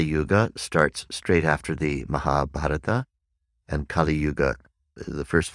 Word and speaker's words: Mahabharata: Mahabharata, which in the West people often say the Mahabharata, but that's a mispronunciation. It's Mahabharata.